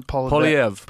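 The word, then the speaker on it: Poilievre: Poliev.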